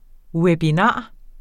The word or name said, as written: webinar